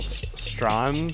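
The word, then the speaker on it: Strachan: Up next, we have Malcolm Strawn, because I was corrected. You don't pronounce the CH, apparently. – CH is silent.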